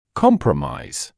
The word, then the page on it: compromise: ‘kɔmprəmaɪz